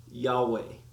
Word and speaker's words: Yahweh: In Hebrew, we would call that, or we would attempt to pronounce that, Yahweh.